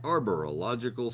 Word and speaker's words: Arborological: And that is sponsored today by the folks at Arborological Services. It is spelled just like it sounds. Arborological. You don't say it with an I, but that's the letter that's in the middle there.